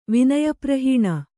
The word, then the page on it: ವಿನಯಪ್ರಹೀಣ: kannada